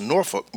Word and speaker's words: Norfolk: So anybody who's been to Virginia, knows anybody from Virginia, has flown into Virginia, you know that the city of Norfolk is pronounced Norfolk by us natives. And yes, it sounds like you are cursing at the time, but that's the correct pronunciation from us on how you pronounce Norfolk. Now, it's spelled Norfolk. And so if anybody who is outside of our community pronounces it Norfolk, we know that they're not from there.